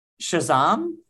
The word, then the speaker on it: Shazam: Shazam? Is that the proper way? Is that the proper way to Shazam? – That's the proper way to say it.